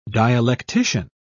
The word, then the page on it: dialectician: British English